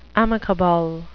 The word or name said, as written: amicable